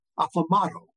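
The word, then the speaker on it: Aplomado: who are the progenitors of Aplomato, rhymes with tomato.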